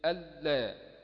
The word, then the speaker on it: ألا: في الرسم أن لا، في النطق إيه؟ ألا،